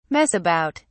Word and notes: mess about: British English

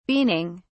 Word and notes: beaning: British English

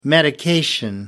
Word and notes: medication: American English

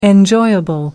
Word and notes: enjoyable: American English

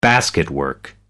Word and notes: basketwork: American English

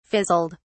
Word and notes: fizzled: British English

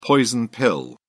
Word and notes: poison pill: British English

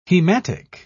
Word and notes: hematic: British English

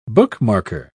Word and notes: bookmarker: British English

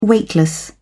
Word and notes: weightless: British English

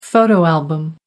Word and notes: photo album: American English

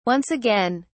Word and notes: once again: British English

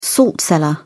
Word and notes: salt cellar: British English

